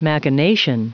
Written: machination

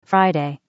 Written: friday

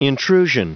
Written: intrusion

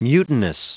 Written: mutinous

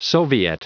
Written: soviet

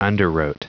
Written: underwrote

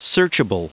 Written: searchable

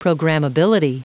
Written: programmability

